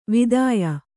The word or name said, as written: ವಾತ್ಯೆ